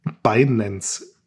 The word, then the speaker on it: Binance: Binance.